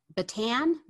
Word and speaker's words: Bataan: And what is the, I don't know if I'm going to pronounce it right, the Bataan Memorial Death March? Am I oh, pronouncing oh, that correctly? – Yes, you are.